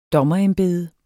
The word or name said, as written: dommerembede